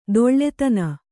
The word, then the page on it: ತೋಳ್ದೀನ್: kannada